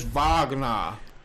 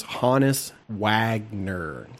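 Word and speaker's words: Honus Wagner: Is it not Wagner? Would it be like, oh, no, it's Wagner. – I looked on Baseball Reference. The pronunciation was Hannes Wagner.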